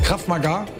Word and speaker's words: Krav Maga: Habe ich das richtig ausgesprochen? Krav Maga? – Auf jeden Fall.